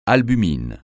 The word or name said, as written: albumine